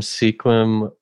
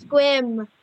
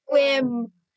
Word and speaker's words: Sequim: Yeah, from Sequim, Washington. – Okay. Man, a lot of people pronounce it Sequim, but it's Squim. – Swim. – Squim.